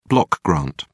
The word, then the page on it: block grant: British English